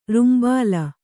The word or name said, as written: ರುಂಬಾಲ